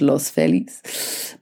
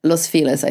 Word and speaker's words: Los Feliz: I always want to say it in Spanish, Los Feliz, but Los Feliz, I think it's pronounced, to go and see a property over there.